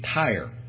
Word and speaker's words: Tyre: And as he made that turn, he realized that there was this little island nation, Tyre. Sounds like the tires on your car, but spelled with a Y instead of an I.